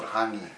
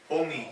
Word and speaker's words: hongi: It's not hungry. – Say it right. So I can learn it. – Hongi.